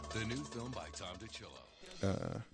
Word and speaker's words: DiCillo: No, directed by Tom DiCillo is how you pronounce it. – Oh, my God. That was That's real. Fun. – Tom. – It's spelled like D-I-C.